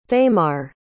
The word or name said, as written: thamar